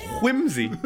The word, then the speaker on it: Whimsy: Whimsy. Whimsy. There is an H in there. – Yeah. – but you don't have to pronounce it. – N- that's true. It's it's optional. It's an optional H.